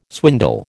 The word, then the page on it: swindle: American English